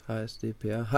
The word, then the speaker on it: HSDPA: Und äh, aber ganz ehrlich, es ist ja auch HDSPA, habe ich richtig gesagt? HSDPA, HSDPA. Plus äh, unterstützt ist, das Neueste da irgendwie von.